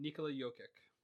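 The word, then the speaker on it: Nikola Jokić: You got Kawhi Leonard, Pascal Siakam, and Nikola Jokic. – Correct. And you pronounced Nikola Jokic correctly 1,000%, which didn't see that coming.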